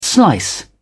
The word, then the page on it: slice: British English